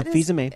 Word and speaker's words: Fils-Aimé: Yeah, Fils-Aimé.